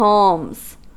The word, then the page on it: palms: American English